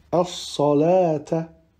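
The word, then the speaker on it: as-salata (الصلوة): Ensuite, dans ces deux cas, on ne prononcera pas le waouh, tel que dans au-dessus, al-hayati, ou bien as-salata.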